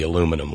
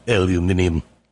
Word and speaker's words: aluminium: Oh, I'm a Louisville Slugger man from way back, but, you know, wooden bats tend to break, so I go with the aluminum one, or as the British say, aluminium, even though there's not an I in front of that U-M in aluminum.